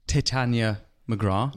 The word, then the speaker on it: Titania McGrath: You're the man behind Titania McGrath.